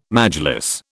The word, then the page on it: Majlis: British English